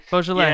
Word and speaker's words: Beaujolais: A good summer wine is, I know I'm going to pronounce it wrong, is Beaujolais or Beaujolais. – Beaujolais, yeah.